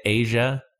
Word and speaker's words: Aja: Say it, Aja. Apparently, that is how you say it.